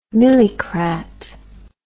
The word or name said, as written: milicrat